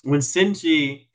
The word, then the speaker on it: Senji: Like, when Seji or, what's his name, Seji? Let me be accurate. When Senji is actually butchering the animals,